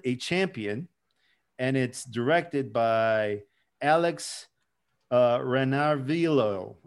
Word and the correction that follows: Ranarivelo: Oh God, I butchered that. Renarvillo. Forgive me, sir. I like Rana Revilo or something like that. – Yeah, Alex Rana Revilo.